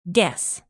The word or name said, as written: guess